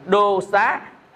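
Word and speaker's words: dosa: À, sân á đọc là đô xá.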